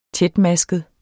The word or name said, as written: tætmasket